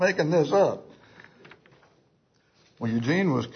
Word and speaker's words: Eugene: If you're from the north, you'll say Eugene, like Eugene, Oregon. But in the south, it's pronounced Eugene.